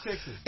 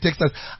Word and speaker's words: Techsys: Texas. It's spelled TECHSYS. But I think it's intentional that they call it Texas.